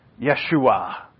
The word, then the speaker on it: Yeshua: By the blood of Yeshua HaMoshiach, which is, and I love the Hebrew pronunciation of these. Yeshua. That sounds so much better than Jesus to me.